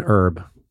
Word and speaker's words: herb: But how would you say herb? – I'd say it's an herb. – Uh-huh, no H. – I wouldn't pronounce the H.